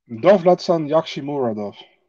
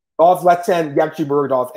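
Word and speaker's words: Dovletsan Yagshimuradov: Bader, I should mention, is fighting the winner of Corey Anderson and Dolvetchen Yagumen. How do you say his name? Tell me his name. – Dovletsan Yakshimuradov. – Dovletsan Yakshimuradov.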